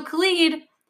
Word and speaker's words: Khalid: So Khalid. – Someone corrected me. – Thank you. – It's Khalid. – Thank you. Okay, yeah. Now I know for sure. So Khalid just has a very effortless voice.